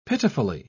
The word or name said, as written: pitifully